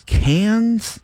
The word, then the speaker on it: Cannes: She was in, how do you say it? Cannes or Can? – Con.